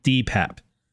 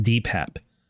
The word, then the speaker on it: DePape: What I want to do is I want to go over the David DePape, which actually, because he was in court today, we learned he likes his name to be pronounced David DePape. So DePape is how he said it, DePape.